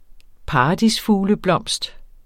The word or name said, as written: paradisfugleblomst